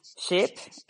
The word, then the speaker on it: ship: Por esta razón, no es lo mismo decir ship o sheep. Ship es barco, sheep es oveja.